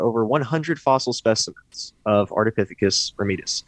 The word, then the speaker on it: Ardipithecus ramidus: And so this guy is pronounced Ardipithecus ramidus. – Yes. – How's that? – That is perfect.